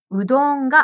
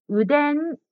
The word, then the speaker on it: うどん: ジムさんのラーメンがラーマンに聞こえました。そしてうどんがうでんに聞こえました。真ん中の文字の発音が少し難しいですね。